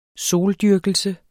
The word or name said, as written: soldyrkelse